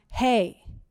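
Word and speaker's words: hey (ה): And number 5 is a letter, and it's it's pronounced hey.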